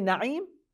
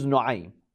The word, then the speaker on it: Nuaym: Some say Naim, but probably the correct pronunciation is Nuaym.